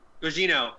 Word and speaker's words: Gugino: I want to say her name is Carla Grigliana. Is that her name? – Gugino.